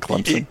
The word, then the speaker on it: Clemson: Yeah, I've had Clemson fans after me for not pronouncing the P in Clemson. – There's a P in Clemson.